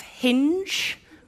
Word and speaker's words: Hinge: Our friend Elizabeth Day Pandora's very happily married, pointed me in the direction of Hinge, rhymes with Minge, which I thought was a...